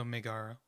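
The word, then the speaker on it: Megaera: I still can't past the first boss, Megara, I think. And then I think it's Megaya, maybe. I mean, I can't remember if it's an E or an R. – It's spelled Maegara, so Megara, or the son of Hades.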